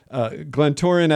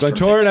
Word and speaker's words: Glentoran: And is this, if I pronounce this wrong, I apologize, but Glentoran FC, how would you say that properly? – Nailed it. You nailed it. – That's Glentoran FC.